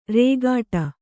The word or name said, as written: ರೇಗಡ